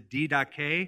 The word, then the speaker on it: Didache: Usually it is mispronounced as the Didache or the Didache, but it's the Didache.